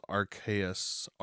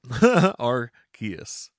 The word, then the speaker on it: Arceus: And then early next year is the new game Pokemon Legends Arceus. I even put down how they pronounced it so I would know. And then I still messed it up. Arceus.